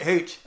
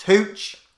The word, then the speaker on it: Hooch: No, mate, pronounce it Hooch. Okay, I'll get it right this time. Okay, go for another take. Turner's Heech? You keep saying Heech. It's Hooch.